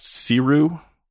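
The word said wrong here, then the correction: Sirui: It's Suray. – Suray. Oh, I'm glad you're here to help me with that. Suray. Okay. Yeah. It's S-I-R-U-I, which I would never have expected was Suray.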